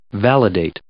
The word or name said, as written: validate